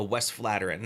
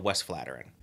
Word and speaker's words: Westvleteren: I we're, we are literally trying a Westvleteren.